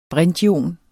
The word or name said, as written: brintion